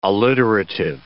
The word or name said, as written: alliterative